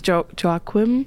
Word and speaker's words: Joaquim: His name's Joaquim, if I'm saying that right. – Yep.